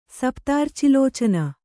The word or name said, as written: ಸಪ್ತಾರ್ಚಿಲೋಚನ